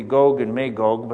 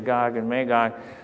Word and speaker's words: Gog and Magog: And I think technically it's a long O, it should be Gog and Magog, but that just sounds weird. So we normally say Gog and Magog.